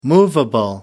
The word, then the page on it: movable: American English